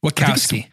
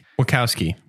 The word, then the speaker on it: Wachowski: What's the last name? Wachowski? – Wachowski. – Wachowski, yeah.